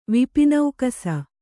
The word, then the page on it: ವಿಪಿನೌಕಸ: kannada